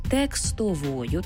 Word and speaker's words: текстовою: До слова, якщо ви ознайомитеся з текстовою, пам'ятаємо про цей правильний наголос, текстовою, так,